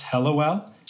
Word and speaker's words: Hellewell: And her last name is Helliwell? – H-E-L-L-E-W-E-L-L. Did I say that right? – Yes.